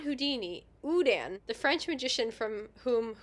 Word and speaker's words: Houdin: I've got the phonetic pronunciation after that. Udan. – The Udan box. The Udan box. No, not Udan. – So why is it funnier when you say it correctly? – It just is.